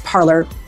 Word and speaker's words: Parler: Okay, so in French, parler is to talk, but it's pronounced parlor, like the parlor in English.